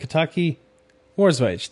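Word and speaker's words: Kataki, War's Wage: And the one you mentioned there, Kataki's War Rage, is that how you say it? Is it Kataki War's Wage, there we go.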